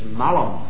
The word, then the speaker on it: malum: The Latin word for evil is malum, M-A-L-U-M. And the Latin word for apple is also Malum, M-A-L-U-M. One is a long A and one is a short A, but you don't need to worry about that.